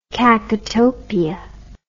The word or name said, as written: kakotopia